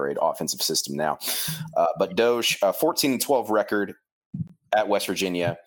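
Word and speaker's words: Doege: And then the big one, in my opinion, former West Virginia quarterback Jarrett Doge. I believe that's how you pronounce it, like the meme.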